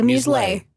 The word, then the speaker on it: muselet: Now, it wasn't until 1844 that the wire cage that fits over the cork, which is called a muselet. – Sorry, muselet? – Muselet. – Can you spell that for us? – M-U-S-E-L-E-T. It's French.